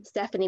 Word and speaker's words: Stephanie: Stephanie with the PH, not with the F.